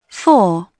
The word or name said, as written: four